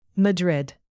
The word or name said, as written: madrid